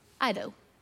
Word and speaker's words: eido: But right here, the word "know" is a word that's spelled E-I-D-O. Ido.